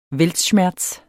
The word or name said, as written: weltschmerz